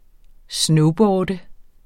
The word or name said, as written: snowboarde